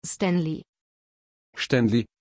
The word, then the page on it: Stanley: German